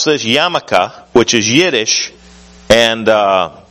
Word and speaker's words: yarmulke: Then this is called the Yarmulke, and the most commonly recognized piece of Jewish garb is actually one with the least religious significance.